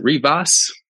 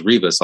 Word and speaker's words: Rivas: People say Rivas. I thought it was Rivas all this time, but yeah. I heard people pronounce it Rivas. – Rivas. You can roll the R if you want.